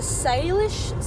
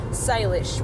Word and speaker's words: Salish: The Salish Sea... So, Salish, which is spelled S-A-L-I-S-H.